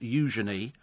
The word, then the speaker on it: Eugenie: And and apparently, Princess Eugenie, pronounced Eugenie, and fiancé Jack Brooksbank, pronounced Jack Brooksbank, are related, which is lovely.